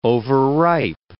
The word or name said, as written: overripe